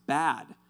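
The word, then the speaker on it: bad (בַּד): And so that word alone, ironically, the Hebrew word for that, the pronunciation of the Hebrew word for alone is pronounced bad. And it's spelled B-A-D.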